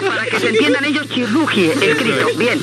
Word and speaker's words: Chirurgi: Lo pronuncio en español. Chirrugie. – Sí, bueno, en español. – Eso es. – Chirrugie. – Eso. – Bueno, que lo correcto sería chirrugie.